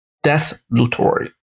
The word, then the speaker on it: desultory: Desolutory. Desolutory. Okay, now this is how they pronounce it.